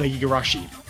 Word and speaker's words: Igarashi: Uh, Koji, uh, I believe it's pronounced like Igor- the Igarashi. – That uh, yes, I believe that's right.